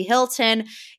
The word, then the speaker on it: Hilton: everyone is assuming Kathy Hilton. Can you tell us why people thought, because I understand why, you know, you said it rhymes with Milton, but why specifically Kathy?